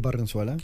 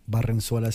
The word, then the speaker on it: Barrenzuela: So I'm currently on Facebook under my own name, Julio Barrenzuela. – Spell that for him. – That's J-U-L-I-O, Julio, and Barrenzuela spelled B as in boy, A-R-R-E-N-Z-U-E-L-A.